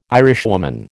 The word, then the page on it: Irishwoman: American English